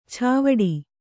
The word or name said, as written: ಚಾವಡಿ